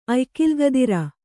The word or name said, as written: ಅಯ್ಕಿಲ್ಗದಿರ